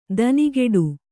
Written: ದನಿಗೆಡು

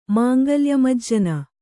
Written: ಮಾಂಗಲ್ಯಮಜ್ಜನ

